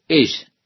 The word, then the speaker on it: esh: La palabra fuego, en el idioma hebreo, es, o se escribe, o se dice, esh, E-S-H. La palabra para decir hombre es ish, ish.